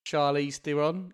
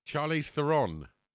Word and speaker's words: Charlize Theron: Oh, I'm pronouncing that a massacre. Here we go. If you haven't listened to this podcast before, you'll soon realise I'm not good with words. – Sorry, Charlize Theron. – That's it.